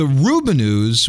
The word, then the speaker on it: Rubinoos: R-U-B-I-N-O-O-S. I assume Rubinoos is how you say it. – That's what it looks like. – I can't imagine pronouncing it any other way.